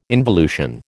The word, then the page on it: involution: American English